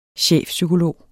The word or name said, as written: chefpsykolog